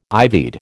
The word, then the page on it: ivied: American English